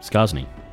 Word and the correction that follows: Sarni: How are you pronouncing it, though? – Sarni? – There we go. Sarni thugs. Because Skarsny was wrong, I've been told.